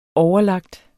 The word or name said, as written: overlagt